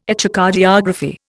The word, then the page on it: Echocardiography: Indian